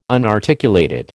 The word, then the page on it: unarticulated: American English